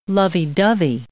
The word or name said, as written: lovey–dovey